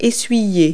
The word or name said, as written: wipe